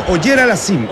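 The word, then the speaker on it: Auger-Aliassime: Pues es, disculpe la pronunciación, Auger Aliassime. – Es Oyer Aliassime, el canadiense. Félix Oyer Aliassime.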